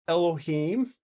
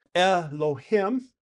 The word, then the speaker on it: Elohim: First, let me tell people that there are three correct pronunciations, lest they think that we're mispronouncing it. – Okay. – So there's one is Elohim, another one is Elohim, Okay. – Elohim, and another one is Elohim. So I hope you saw the difference.